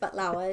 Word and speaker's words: baklava: They're going to teach everyone how to make baklava, which is pronounced batlawa is how we say it.